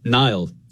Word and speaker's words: Niall: When I was growing up, I would say Niall, like N-I-L-E effectively, like the river.